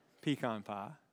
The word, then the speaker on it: pecan: If you're curious, that's how you pronounce it correctly pecan pie.